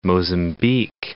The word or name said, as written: Mozambique